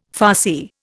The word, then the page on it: Farsi: Indian